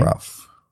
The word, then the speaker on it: Ralph: Ralph. – Because it's spelt completely different to the way you say it. Because I always thought it was Ralph Fiennes, but it's Ralph Fiennes.